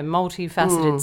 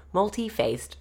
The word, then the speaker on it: multifaceted: She writes that she can't wait to show him her multifaceted spirit. – I actually think, whether she wrote it wrong. Is that a metaphor for her bujoy? – He pronounced it wrong. He said multi-faced.